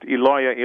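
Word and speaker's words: Eloi: And in fact, when Jesus calls out, my God, my God, it's pronounced Eloia, Eloia in Aramaic, which sounds identical to the name Elijah in Aramaic.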